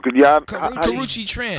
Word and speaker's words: Karrueche Tran: I keep saying her name wrong. – Karrueche Tran.